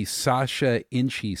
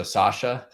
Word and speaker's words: Sacha Inchi: So you got the pronunciation right, which is great. Not everybody does that. So you definitely, I know you have some familiarity with it. – What do they call it if they don't call it Inchi? – You know, it's funny. We hear Sacha Inchi quite a bit. We hear just Inchi seeds. So we hear a little bit of everything. Sometimes a soft cha, so Sacha. It's all over the place, but nonetheless, it's none about the pronunciation anyway.